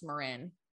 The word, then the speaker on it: Marin: It's actually pronounced Marin. – Marin. – I think. Hold on. – Yeah, Marin. – Yeah, it is Marin. I haven't been there in a long time. It's a really beautiful part of the country, or of California. – Yeah. I was reading it how it looked. – That is how it looks. It is spelt Marin, but it's Marin County. M-A-R-I-N.